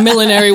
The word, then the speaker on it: millinery: When I first started, I didn't even know what millinery was. I was like, oh. – Oh, I'm saying it wrong. – Millinery. – Oh, I'm saying it wrong. – Yeah. – I'm saying millinery. Millinery.